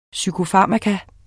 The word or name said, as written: PSYKOFARMAKA